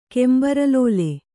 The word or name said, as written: ಕೆಂಬರಲೋಲೆ